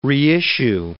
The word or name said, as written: reissue